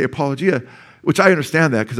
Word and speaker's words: apologia: Just say the word apology with me. Apology. Apology. I'll just add a at the end. Apologia.